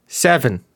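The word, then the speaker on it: Cefn: Apparently it's hang on. Seven. That's how seven. – Seven? – it sounds like you're trying to weirdly pronounce seven.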